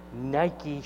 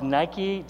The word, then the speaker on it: Nike: He said, these are genuine Nike shoes. And by the way, in case you didn't know, the chairman of Nike just confirmed it's Nike, it's not Nike.